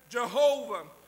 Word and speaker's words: Jehovah: Sometimes we pronounce it as Yahweh. Sometimes it would be pronounced as Jehovah.